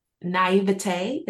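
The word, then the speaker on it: naïveté: I fall a bit into naivete. Is that how you say it? – Yeah.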